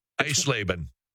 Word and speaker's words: Eisleben: No, he died in, I'm gonna butcher this, Iselbein? I mean. – Eisleben.